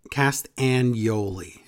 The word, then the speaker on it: Castagnoli: Castagnoli is how you pronounce it.